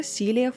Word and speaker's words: Силия: Итак, спустя 8 лет после возвращения Дэвида с войны, 13 марта 1973 года рождается Силия Фрэнсис Роуз – и ее имя только пишется как Силия, но произносится как Сили.